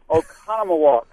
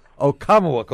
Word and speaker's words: Oconomowoc: Oconomowoc, Wisconsin. Did I say that right? – Oh, that was a nice effort. Oconomowoc. – Oconomowoc.